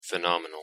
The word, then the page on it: phenomenal: English